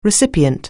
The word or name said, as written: recipient